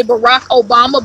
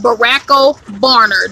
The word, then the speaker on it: Barack Obama: Okay, this person was trying to say Barack Obama, but they said Barack O'Burner.